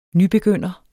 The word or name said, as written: nybegynder